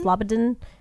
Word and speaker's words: Slobodin: Oh, wait, I think you forgot to mention, though, that Slobodin, or how do you say that? – Slobodin.